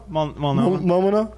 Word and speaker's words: Multnomah: Multnomah.